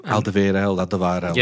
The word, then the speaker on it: Alderweireld: Alderweireld.